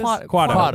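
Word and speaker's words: Quato: Like a Guado. Is that what it is? – Quado. We always thinking of Quado.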